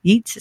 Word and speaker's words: Yeats: Yeats, is that how you say his name? Yeah.